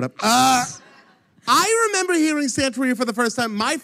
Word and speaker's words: Santeria: So can you tell me what it is about Santeria by Sublime that you love? – And you have to say it like that, Santeria. – Sorry. – Santeria.